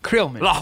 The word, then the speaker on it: Krillman: Is it Krillman or Krillman? Like Krill? – Krill. – Krill. – Krillman.